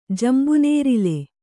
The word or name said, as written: ಜಂಬುನೇರಿಲು